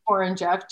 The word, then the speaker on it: Horonjeff: J Horonjeff J H O R O N J E F F.